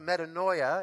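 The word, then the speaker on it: metanoia (μετάνοια): The Greek word in the New Testament for repentance is a word that's pronounced metanoia, and it means to change one's mind and to change one's behavior, to turn around.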